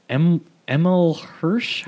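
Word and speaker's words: Emile Hirsch: Emil Hirsch.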